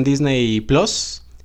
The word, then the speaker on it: Disney+: Se estrenó directamente en Disney Plus, así se pronuncia, no, no Disney Plus como se escribe, pero bueno, sabemos que no hay mucha gente que tenga Disney Plus todavía.